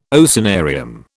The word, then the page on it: oceanarium: British English